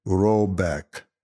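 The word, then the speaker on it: Råbäck: The manor house, or Ergard, in question is to be called Hreibak, pronounced something like Robeck, though that is not its name.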